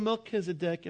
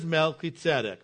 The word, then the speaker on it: Melchizedek: You can call him Melchizedek if you like, but the pronunciation is Melchizedek.